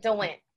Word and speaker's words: DeWindt: Did I say your name right? – Yeah, you did. DeWitt.